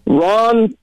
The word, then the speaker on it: Ron: Ron rhymes with John.